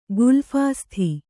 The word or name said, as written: ಗುಲ್ಫಾಸ್ಥಿ